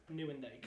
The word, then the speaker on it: Nieuwendyk: And then number 25, I'm going to butcher the hell out of this, Joe Nieuwendyk. – Yes. – Joe Nieuwendyk, he's my honorable mention.